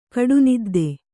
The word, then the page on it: ಕಡುನಿದ್ದೆ: Kannada